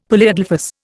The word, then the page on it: polyadelphous: Indian